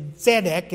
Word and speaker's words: Zedek: Is Zedek, is sort of how it's pronounced in Hebrew, is righteousness.